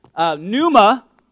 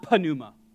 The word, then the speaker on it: pneuma: It's pronounced pneuma, not panuma. I was calling it panuma for many years, and then somebody who knows Greek better than I said, the P is silent.